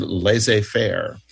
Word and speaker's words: laissez-faire: And the letter Z is silent in the word laissez-faire.